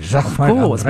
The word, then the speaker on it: Jacquemart-André: You say it like a Frenchman. – Jacquemart-André. Because I'm French. So Jacquemart-André.